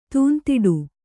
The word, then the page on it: ತೂಂತಿಡು: tūntiḍu